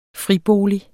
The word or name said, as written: Fribolig